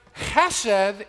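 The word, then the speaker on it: chesed (חסד): We pronounce or translate or, well, we phonetically spell it this way often, C-H-E-S-E-D. But it's pronounced with a kind of a guttural chesed.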